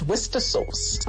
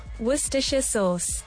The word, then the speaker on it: Worcestershire: And the correct pronunciation actually is Worcestershire sauce. – Yeah, there we go.